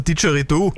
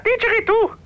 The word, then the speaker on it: Didgeridoo: Ach so, du meinst ein Didgeridoo? – Ja, genau, ein Didgeridoo.